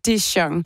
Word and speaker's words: DeJong: Det er den amerikanske kunstner DeJong. Og ja, det er navnet, du udtaler, ligesom din DeJong Senap.